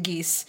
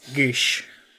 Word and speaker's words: Geas: G-E-A-S. – In my brain, I will only pronounce it geese. – Gish. Gish. – Gish. – Geese. – Gish. And that was good enough, so...